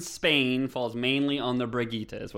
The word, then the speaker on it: Brigitte: I believe it's Bridget. – It is pronounced Brigitte.